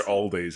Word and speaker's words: Aldi: No, it's probably Tesco first, then Lidl, then Aldi's, then How do you say that one? – Aldi.